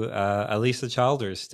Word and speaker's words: Alisa Childers: So welcome to Alisa Childers.